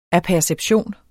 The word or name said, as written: apperception